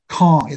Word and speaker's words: Kang: Law professor Jerry Kong. It's spelled Kang, but it's Korean. Long story. I used to work with a guy. – Right. – It's K-A-N-G. It's pronounced Kong.